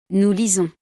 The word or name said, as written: nous lisons